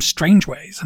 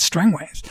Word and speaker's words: Strangways: I've heard an audiobook where they called him Strangeways, and it's like, no, it's Strangways. – It's Strangways. – he learns from local station chief Strangways